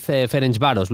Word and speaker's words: Ferencváros: Resto de eliminatorias: Unión San Gilua Ajax, Twente Bodo Glimt, Fenerbahce Anderlecht, AZ Galatasaray, PAOK FHSB y Ferencváros Victoria Pilsen. Me ha gustado, como decía Aduriz, Ferencváros.